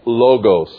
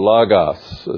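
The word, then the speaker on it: logos (λόγος): In Greek, the word is logos or lagos, as some people say. L-O-G-O-S is how we would spell it in English.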